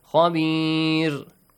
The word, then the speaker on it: خبير: mouth والله بما تعملون خبير را Ra shouldn't be pronounced with a heavy sound because there is a kasra sound before. Khabir.